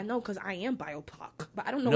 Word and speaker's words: BIPOC: Oh, it's pronounced BIPOC.